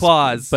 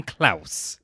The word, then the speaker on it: Klaus: You keep calling it Claws. – It's Claws. – But every time I see that name spelled with a K, I know. – I'm going to call him Klaus.